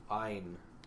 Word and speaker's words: Ayn: Ayn. – Yeah, it rhymes with mine.